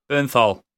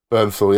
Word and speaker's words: Bernthal: Jon Bern, Bernthal, is that how you say his name? Bernthal. Bernthal, yeah.